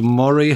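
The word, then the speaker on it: Moray: No, it's The Spire Group, S-P-Y-R-E. It's called Murray House. So that's, well, you would pronounce it Murray, but it's M-O-R-A-Y, like the Firth in the northeast of Scotland, or Eels.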